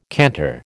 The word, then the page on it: canter: American English